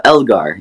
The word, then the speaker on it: Elgar: Elgar.